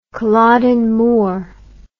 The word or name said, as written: culloden moor